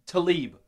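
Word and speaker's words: Tlaib: Talib.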